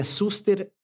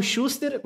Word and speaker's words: Szuster: O sobrenome é Suster, só que ele se escreve S-Z-U-S-T-E-R que teoricamente da Polônia eles falam Schuster, mas eu sempre falei Suster, então Schuster, Suster, tá Schuster certo. ou Suster, enfim.